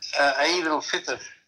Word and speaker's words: Eid al-Fitr: How do you say Eid al-Fitr? – Eid al-Fitr. – Al-Fitr. Oh, he gives it a whole other syllable.